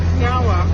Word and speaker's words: nyawa: So in our language we say nyawa, which means thank you. – Nyawa. – Nyawa. – I said it right? – Yeah, you did.